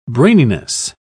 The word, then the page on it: braininess: British English